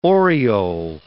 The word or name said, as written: oriole